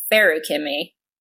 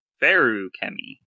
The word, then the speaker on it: Furukami: With the emphasis on the second syllable, so it's Furukami. – Furukami.